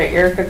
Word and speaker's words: Erica: Dr. Erica Curry, E-R-I-C-A-C-U-R-R-Y.